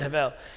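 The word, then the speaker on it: hevel (הבל): Okay, here's what he said about this word hevel. He says, one must aspirate twice with the initial huh sound and then again with the soft bet pronounced as vel.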